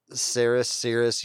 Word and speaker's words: Ceres: Is that Ceres? Because I never know how I'm pronouncing that. – Ceres, yeah. Either one will work.